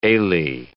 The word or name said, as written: alae